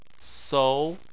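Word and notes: sou2: tone 2

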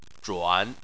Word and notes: zhuan: 3

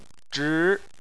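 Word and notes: zhi: tone 2

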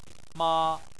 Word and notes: ma: 1

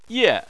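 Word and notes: ye: tone 4

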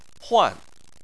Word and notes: huan: 4

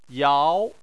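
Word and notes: yao: tone 2

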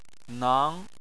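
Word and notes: nang: tone 2

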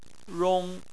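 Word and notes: rong: tone 1

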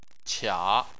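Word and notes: qia: tone 3

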